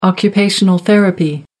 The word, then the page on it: occupational therapy: American English